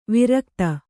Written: ವಾರಗಿತ್ತಿ

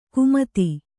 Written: ಕುಮತಿ

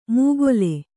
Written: ಮೂಗೊಲೆ

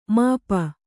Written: ಮೋಪು

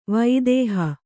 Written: ವಿದೇಹ

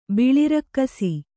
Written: ಬಿಳಿರಕ್ಕಸಿ

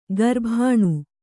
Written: ಕರಬೋನ

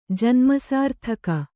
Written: ಜನ್ಮಸಾರ್ಥಕ

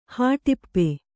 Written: ಹಾಟಿಪ್ಪೆ

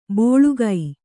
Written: ಬೋಳುಗೈ

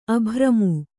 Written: ಅಭಿರಾಮ

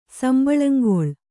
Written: ಸಂಬಳಂಗೊಳ್

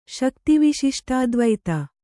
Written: ಶಕ್ತಿವಿಶಿಷ್ಟಾದ್ವೈತ

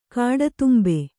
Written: ಕಾಡತುಂಬೆ

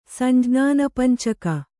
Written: ಸಂಜ್ಞಾನಪಂಚಕ

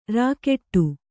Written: ರಗಡು

